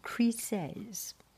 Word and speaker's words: Chryses: C-H-R-Y, Chryses. Chryses. I will check dictionary. Okay, wait. Okay, I checked dictionary and it pronounced Chryses.